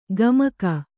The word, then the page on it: ಗಮಕ: gamaka